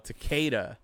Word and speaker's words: Takeda: Oh, Takeda. – Yeah. T-A-K-E-D-A.